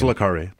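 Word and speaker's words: Le Carré: I don't know how to say his name. – It's Le Carre. – Le Carre. There we go.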